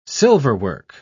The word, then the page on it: silverwork: British English